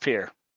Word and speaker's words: Pierre: Our state capital, spelled Pierre, pronounced Pierre leave it at that.